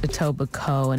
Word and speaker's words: Etobicoke: Who would think that Etobicoke is pronounced Etobicoke and not Coke? – Coke.